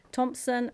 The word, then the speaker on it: Thompson: Yeah, my name is Amanda, that's A-M-A-N-D-A. Thompson, which is spelt T-H-O-M-P-S-O-N.